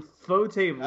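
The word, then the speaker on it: ufotable: UFO table. – That's how it's pronounced. It's UFO table.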